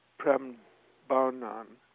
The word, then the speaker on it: Prambanan: I never said it right. Prambanan.